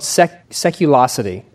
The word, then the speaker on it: Seculosity: It's called Seculosity. Did I pronounce that right? – Yeah. That's it.